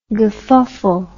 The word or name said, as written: gefufful